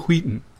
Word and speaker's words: Wheaton: Will Wheaton, I think, is the correct pronunciation. – Will Wheaton. No, because it's only one WH. It's Will Wheaton.